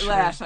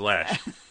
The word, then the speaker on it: Loesch: Lash. I mean, Dana Lash. – I don't even know how that name's pronounced, Lash. It says Loesch. – And anyway. – It said, no way. It says, it's L-A. It's not L-A-S-H.